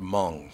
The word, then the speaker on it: Hmoob: I still want to know how H-M-O-O-B is pronounced Hmong.